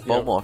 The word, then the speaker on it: Bowmore: Bowmore, is that how you pronounce that? – Yeah, Bowmore.